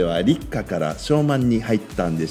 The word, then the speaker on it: 小満: それが終わって今昭満っていうのがね始まっていますあらゆる生命が満ち満ちてくる時期だから満ちる満足の満と書いて、えー、小さな満足小満と読むんですね、